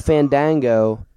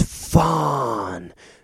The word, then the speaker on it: Fandango: Oh, I need to breathe in the A's. It's not Fandango. – Go. – He said it right. It's Fandango.